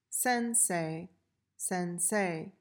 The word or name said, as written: sensei